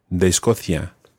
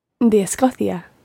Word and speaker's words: de Escocia: And notice with things like de Escocia, it runs together. De Escocia. – De Escocia. – Yeah, it's still written as two words, de Escocia, but it does run together when you're pronouncing it.